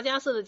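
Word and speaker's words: 迦叶: 再来是罗提迦瑟，罗就是这个那个的罗，提就是提东西的提，迦瑟迦就是一个大迦瑟的迦，加减乘除加一个加个走字旁，迦瑟，瑟就是叶子的叶，叶子叶要读成瑟。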